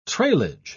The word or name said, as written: treillage